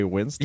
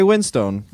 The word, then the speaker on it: Winstone: We wildly get wrestling facts wrong, but it's Ray Winston. – Yeah, but our podcast is called The Film Review. – No, it's Ray Winstone. It's not Ray Winston. Maybe they pronounce it Winston, but it's Stone. You piece of shit.